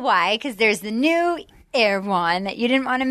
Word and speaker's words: Erewhon: Somebody in my DMs was like, it's pronounced Erewhon.